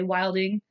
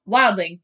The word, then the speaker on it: Wildling: Wildling. Because I'd always say it wrong. Yes. I have the note around here somewhere to remind myself to not say Wilding. She's like, you're saying it wrong. Wildling.